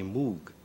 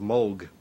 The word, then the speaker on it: Moog: In Great Britain, English-speaking countries, people see the double O and they say moog. Now, I just happen to like the sound of moog better than moog. Part of my family pronounces it one way, and part pronounces it the other way.